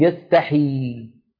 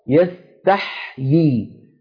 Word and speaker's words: يستحيي: فيقولون يستحي يستحي هذا لا يصح لا بد من نطقها هكذا يستحيي لاحظوا تح الحاء تبقى على سكونها إن الله لا يستحي يستحيي أن يضرب مثلا هكذا تنطق هذه الكلمة